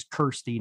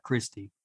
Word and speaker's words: Kirsty: Well, I'll tell you the first thing I think is, according to the Hellraiser wiki, her name is Kirsty, not Christy.